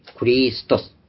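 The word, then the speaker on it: ク リ スト ス。 (Χριστός): すべてに、エンパーシンですべてのものの中にクリストス。これが主語なんですね、クリストスが。発音もクなんです。クリストス。日本でかキという発音になっています。キリストっていうのは本当に、だからね、日本だけですね。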